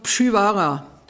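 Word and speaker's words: Przywara: Geboren 1889 in Katowice, Oberschlesien, heute Polen, und der Vater war Pole, daher der Name, ähm, richtig ausgesprochen Pschivara. Es gibt jede Menge Aussprachen, die sind nicht gut.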